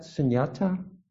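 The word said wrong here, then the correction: śūnyatā: How do you pronounce shunyata? – It? Shunyata. I didn't know the proper pronunciation.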